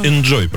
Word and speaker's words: inZOI: Сейчас же новый Sims Inzoi, который этот корейский есть, который типа супер проработанный Sims Inzoi называется. – А его можно? – Enjoy, правильно говорить.